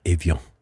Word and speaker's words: Evian: Say this when I say it wrong? Evian? Avion? – Avion.